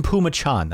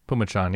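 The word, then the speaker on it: Phommachanh: Tyson Pumachan, that's how you say his name. We looked it up. – Pumachan, yeah.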